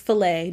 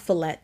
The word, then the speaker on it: filet: I just want to say that for all the people that kept saying sirloin, it's filet, not fillet.